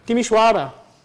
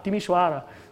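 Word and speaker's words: Timișoara: Did I pronounce this right, more or less? Not quite? Timisoara. Ah, Timisoara.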